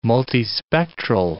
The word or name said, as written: multispectral